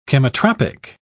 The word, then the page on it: chemotropic: British English